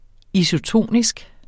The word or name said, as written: isotonisk